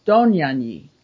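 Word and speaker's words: Dohnanyi: And it said that he pronounced, the Hungarian pronunciation of his name is the emphasis on the first syllable, Donjanyi. In Hungarian, Donjanyi. How, of course, I don't know exactly. I'm doing that quite right, but he then changed it. He changed the pronunciation to the accent on the Donjanyi, so it wouldn't sound so Hungarianish.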